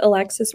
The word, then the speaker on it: Alexis: So my Instagram is Alexis Raleigh.